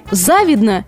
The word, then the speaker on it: завидно: Итак, как же правильно сказать? Мне завидно или мне завидно? В сторону всякие сомнения запоминаем раз и навсегда. Ударение на «и». Завидно. И ударение это не переходящее.